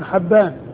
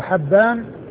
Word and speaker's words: حبان: فحبان هو الفتح الهائي وليس بكسرها، وهو ابن هلال وهو ثقة حديثه عند أصحاب الكتب الستة.